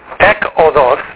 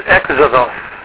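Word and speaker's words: exodos (ἔξοδος): So instead of ekodos, it becomes exodos.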